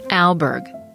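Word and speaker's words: Alburgh: Alberg. Al like pal.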